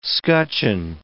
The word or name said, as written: scutcheon